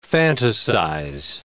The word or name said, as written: fantasize